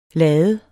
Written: lade

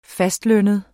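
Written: fastlønnede

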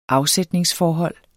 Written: afsætningsforhold

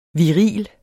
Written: viril

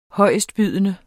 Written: højestbydende

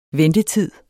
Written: ventetid

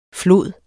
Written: flod

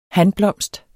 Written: hanblomst

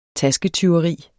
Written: tasketyveri

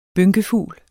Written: bynkefugl

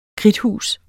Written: kridthus

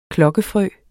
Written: klokkefrø

